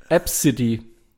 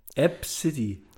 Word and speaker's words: Abcde: App City wird es ausgesprochen. – App City. – Wenn du das allerdings nicht weißt, dass es so ausgesprochen wird, dann steht da A, B, C, D, E.